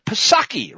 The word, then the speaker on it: Psaki: White House Press Secretary Jen Psaki, remember, the P is silent, folks, like it is in resident, snarked, the tragedy of the treadmill that's delayed.